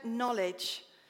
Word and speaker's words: knowledge: but I'm actually going to use the word knowledge, that silent K in the word.